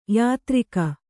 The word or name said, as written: ಯಾತ್ರಿಕ